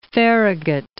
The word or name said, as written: Farragut